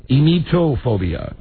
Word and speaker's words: emetophobia: This would be a mephophobia. – A mephophobia. – Emetophobia. Emetophobia. – Spell it. – E-M-E-T-O, emetophobia, P-H-O-B-I-A.